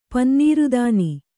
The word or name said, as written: ಪನ್ನೀರುದಾನಿ